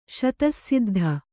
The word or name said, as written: ಶತಸ್ಸಿದ್ಧ